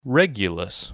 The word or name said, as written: Regulus